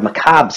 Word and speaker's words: macabre: You know, since I let that word macabre slip. – Said it right.